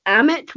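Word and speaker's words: Ammit: However, it was adamant. Is that how you pronounce that? Adamant? Amit, yeah. Sorry, the damned souls, not Anubis.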